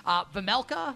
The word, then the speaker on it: Vemelka: Vemelka, is that how you pronounce it, right? – Yep.